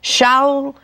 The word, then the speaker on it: Shaul: And he said to Saul, he would have pronounced it with the Hebrew pronunciation, Shaul, Shaul, why persecutest thou me?